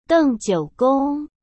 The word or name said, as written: dengjiugong